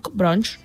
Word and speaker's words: Bronx: Bronx, no sé com es pronuncia mai això.